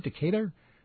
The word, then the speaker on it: Decatur: Corey Kretzinger, 25-year-old from Decatur, Illinois. Is that how you say it, Bernie? Decatur? – Yes.